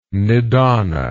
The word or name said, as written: nidana